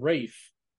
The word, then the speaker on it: Ralph: Ralph Fiennes is his name, how he pronounces it, but you're right with Ralph Finnis. I mean, it's spelled that way phonetically. – Well, someone yeah. said Rafe.